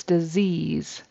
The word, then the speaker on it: disease: And disease actually has a Z in the middle and a Z on the end. Even though they're spelled with S's, it's disease.